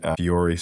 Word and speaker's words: Fiori: You've written about SAP HANA, SAP Fiori Security, if I'm saying that right.